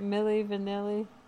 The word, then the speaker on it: Milli Vanilli: Isn't that how you say it? Milli Vanilli? – Yeah. – Yeah.